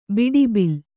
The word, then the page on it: ಬಟ್ಟಬೈಲು: baṭṭa bailu